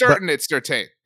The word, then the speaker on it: Surtain: I mean, we said Surtain. I'm probably mispronouncing it, whatever. – It's Surtain.